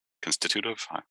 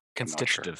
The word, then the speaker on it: constitutive: How would I pronounce that more Britishly? Constitutive? – Constitutive. – Sure. Constitutive, thank you.